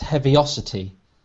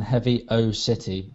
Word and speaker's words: Heaviosity: Now, I believe that this will be pronounced Heaviosity. – Perhaps. – Rather than Heavy O City, which sounds like some kind of Irish destination.